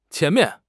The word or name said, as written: qian2 mian4